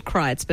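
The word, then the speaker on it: Kreuz: All of the Texan barbecue joints have their traditions in old meat markets from German and Czech settlers that was there, so it's called Kreitz, but it's spelled very, very Germanic.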